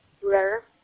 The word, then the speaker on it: Brer: Yes, it's Moat Brer. It's M-O-T-H. Last name is spelled B-R-E-R.